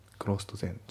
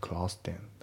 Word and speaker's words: closed then: まあ発音ですけどクローストの D が抜けますね。 Closed then。クロスト全じゃなくてクロステンだね。